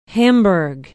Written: hamburg

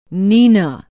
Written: Neenah

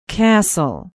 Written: cassel

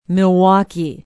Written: Milwaukee

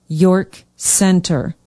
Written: york center

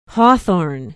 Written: hawthorne

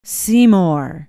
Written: Seymour

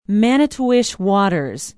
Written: manitowish waters

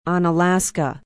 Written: onalaska

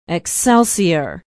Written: Excelsior

